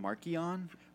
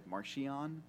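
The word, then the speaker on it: Marchion: The authors pronounce it Markian. But when they gave Mark Thompson the audiobook, they didn't tell him the right pronunciation, so he said Martian.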